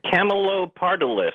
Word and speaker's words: Camelopardalis: Camelopardalis.